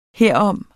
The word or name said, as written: herom